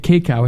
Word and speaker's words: cacao: The other one was roasted, and I can never pronounce this correctly. It's cacao.